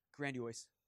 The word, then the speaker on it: grandiose: I was let somebody let me know last week that I was saying grandiose wrong. Get it. Grandiose. Grand, how do you say it? Grandiose. I'm putting an O in the wrong place.